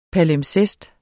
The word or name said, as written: palimpsest